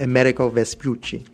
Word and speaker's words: Amerigo Vespucci: Amerigo Vespucci.